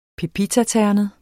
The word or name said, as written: PEPITATERNET